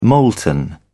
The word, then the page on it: molten: British English